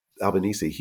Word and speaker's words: Albanese: Albanese.